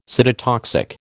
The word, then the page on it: cytotoxic: American English